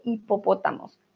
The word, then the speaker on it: hipopótamos: Muy bien, en el zoológico había jirafas, cebras, cebras perdón, e hipopótamos. Tenemos una H, claro que está ahí, pero no la pronunciamos.